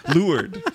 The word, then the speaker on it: leeward: Leeward. – The lured. I said it wrong.